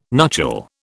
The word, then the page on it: nuchal: British English